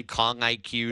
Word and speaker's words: Kong IQ: Am I saying that correctly? Kong IQ, yeah. That sports performance and wellness platform? – Yeah, I mean, you're correct on pronunciation, but it's kind of like, it is a NIL deal technically, but it's more like a program deal.